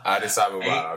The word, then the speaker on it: Addis Ababa: Bole, Hababa. Yeah. And is that Habiba? – Yeah, Addis Ababa. – Addis Ababa, okay.